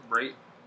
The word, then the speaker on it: Brate: I'm gonna go Connor Brat, Bratt. Is that how you pronounce it? Brate. Cameron Brate.